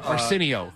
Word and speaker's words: Arsenio: I couldn't do it because I was watching Enchirio Hall, where... – Arsenio is how you pronounce it.